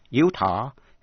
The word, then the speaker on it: Utah: Utah, đánh vần là U-T-A-H, có hai cách đọc, Utah hay là Utah.